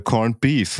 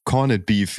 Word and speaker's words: Corned Beef: Es ist ja, ist ja quasi was, was von den britischen Besatzern rübergebracht wurde und es heißt äh, Corned Beef und nicht Corned Beef, wie das, äh, wie das halt ausgesprochen wird, wie, äh, hm, okay.